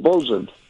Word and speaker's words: bosun: That's called bosun.